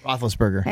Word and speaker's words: Roethlisberger: Meanwhile, the Steelers quarterback, Ben Roethlisberger, if I'm saying that correctly. – Roethlisberger.